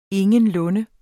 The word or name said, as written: ingenlunde